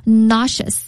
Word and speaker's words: nauseous: 我给大家拼一下 nauseous，它的发音是 nauseous。 – nauseous 这个词注意啊，后面可是一个翘舌音哈， nauseous。